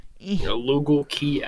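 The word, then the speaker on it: Lugal Ki En: Is that how you say it? Something like that? Like Lugal? – Lugal Kien. Lugal Kien. There we go.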